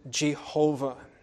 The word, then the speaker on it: Jehovah: When we put the vowels in, we pronounce it Jehovah.